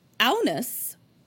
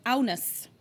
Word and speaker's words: Agnes: Aunus, which is spelled Agnes, but In the Dutch or Holland culture, it's pronounced Aunus.